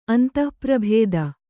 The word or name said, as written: ಅಂತಃಪ್ರಭೇದ